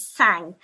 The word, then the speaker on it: Tsang: So my actual surname is Sang, which is spelled T-S-A-N-G.